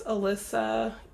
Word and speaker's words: Alyssa: If you want to find me on Facebook, it's Alyssa. Like, it's spelled like Melissa, but no M.